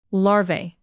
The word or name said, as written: larvae